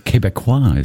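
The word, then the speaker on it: Québécois: Quebecois is how you say it.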